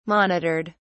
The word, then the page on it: monitored: British English